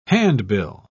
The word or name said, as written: handbill